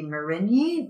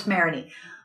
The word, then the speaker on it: Marigny: I have to learn how to pronounce these the Nolens way, not how they would be actually pronounced in French. Because I took French, not that I know a lot of French, but I like go to, well, that should be Marigny. No, it's Marigny. Okay, and like we're on Chartres, as in Chartres Cathedral in France.